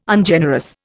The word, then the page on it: Ungenerous: Indian